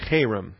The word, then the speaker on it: Cherem: C-H-E, if you want to put a line over the E, you can. That first E is a long E. R-E-M, the second E is a short E. And so we pronounce it Cherem, number 2764.